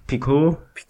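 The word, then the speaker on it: Picault: Picot, so französisch wird das ausgesprochen.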